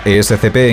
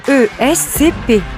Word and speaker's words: ESCP: Pero, ¿sabes cómo se pronuncia ESCP en cada uno de ellos? – ESCP. – ESCP. – ESCP.